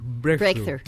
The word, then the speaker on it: Breakthrough: Poco tiempo después, gracias al trabajo de tantas personas para poder sacar esta imagen, ganan un premio que es el premio Brewster, me Premio Breakthrough. – Breakthrough. Tengo ese problema en la pronunciación.